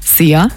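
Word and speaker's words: Szia: Wird einfach ausgesprochen wie unser Energy Star. Sia.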